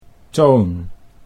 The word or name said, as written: Toon